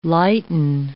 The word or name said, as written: lighten